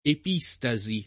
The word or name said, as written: epistasi